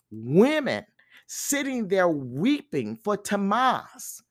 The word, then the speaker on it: Tammuz: The holy place was reserved for priests, and it was here that the women were worshiping the pagan god Tammuz, by the way, spelled T-A-M-M-U-Z, pronounced Tammuz, who was associated with immoral and impure rites.